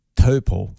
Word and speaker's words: Taupo: Now, there's an issue in our lovely central North Island town beside the lake, Taupo. – Oh, okay. – Taupo. – Yeah, great. – Taupo.